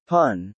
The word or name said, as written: pun